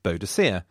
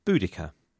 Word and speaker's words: Boudica: I was brought up to say Boadicea, yet in recent years people tend to say Boudica, and I'm happy to change with the times.